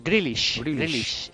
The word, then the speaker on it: Grealish: ¿Cómo se pronuncia? ¿Grealish? – Grealish.